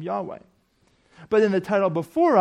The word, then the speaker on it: Yahweh: That indicates that the word God here is translating God's personal name, which in Hebrew is pronounced Yahweh.